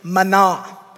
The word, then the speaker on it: mina: Now, that word mina, there is some discrepancy in its pronunciation. Some pronunciation guides say that it's actually mana.